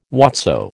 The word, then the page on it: whatso: American English